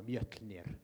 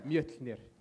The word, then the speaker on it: Mjölnir: God of Thunder with his hammer, Mjölnir, if he was a white. – Very good. Very well pronounced. – Wait, do that again. Do that again. – Mjölnir.